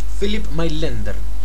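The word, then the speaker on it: Philipp Mainländer: Philip Mailänder.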